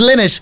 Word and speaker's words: Lynott: I'm trying to train myself to say Lynott. Everyone says Lynott. Apparently he pronounced it Lynott, and I met a Lynott recently and asked them, and yes, that's how they pronounced it too.